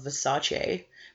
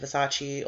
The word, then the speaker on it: Versace: She's really sick of people pronouncing it Versace or whatever. So if I say Versace, it's only because that's how Donatella says it.